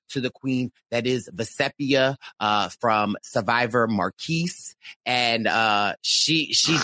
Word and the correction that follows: Marquesas: just, she, did I say it wrong? Marquesas.